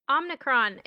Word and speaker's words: Omicron: Omni character. – Omicron is how you say that.